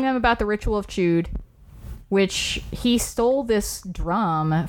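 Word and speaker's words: Chüd: It's chewed. I'm going to call it the Ritual of Chud. And you guys are going to sit there and you're going to take it. Because it's spelled C-H-U-D. Yes, I know there's an umlaut on the U.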